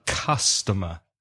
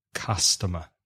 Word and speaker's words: customer: It's C-U-S, but it's not read or pronounced in the same way as you would read it in Spanish. Cu. It's pronounced ka, ah. So customer, customer.